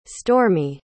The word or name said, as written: stormi